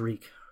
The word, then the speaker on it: Reek: My name is Reek. Rhymes with leak.